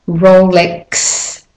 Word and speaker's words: Rolex: Okay, so the first one is Rolex watches. So you've got to go X, W, the KS sound, even though it's spelt with an X, to a consonant W, yeah?